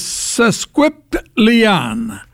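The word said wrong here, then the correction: sesquipedalian: I guess that's a horrible pronunciation. – It is? – Yeah. – How is it said correctly, Kevin? – Sesquipedalien.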